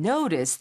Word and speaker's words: notice: notice도 O 발음이고요. hole 발음도 O가 됩니다. 그냥 notice, hole이 아니라 notice that hole 해서 약간 O 발음에 약간만 좀 신경 써주시면 좋을 것 같아요.